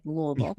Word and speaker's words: Louisville: Notice I said Louisville. – That's right. You pronounced it correctly.